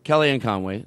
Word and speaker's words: Kellyanne Conway: Uh, they were talking to Kellyanne Conway. Did I say her name yes.